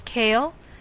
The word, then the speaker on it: Kale: Sure, it's Cale. That's spelled K-A-L-E.